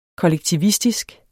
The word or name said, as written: kollektivistisk